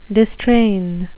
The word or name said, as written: distrain